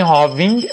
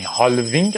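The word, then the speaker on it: halving: هاوینگ اتفاق مهمی هالوینگ قدیما بهش میگفتیم ولی فهمیدیم که الش تلفظ نمیشه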